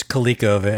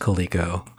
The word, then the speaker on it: Coleco: It's Coleco. I mean, it comes from the word leather, but it's pronounced Coleco.